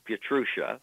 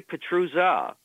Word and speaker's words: Pietrusza: Well, the standard pronunciation in this country is Pietrusza. She would say Pietrusza,